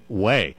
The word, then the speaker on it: Hue: And it's pronounced, listeners, if you're in a bookstore or looking online for Mark's book, it's spelled H-U-E, but it's pronounced Wei.